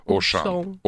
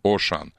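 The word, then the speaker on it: Auchan: Он даже произносится Ошан. Ошан. Да.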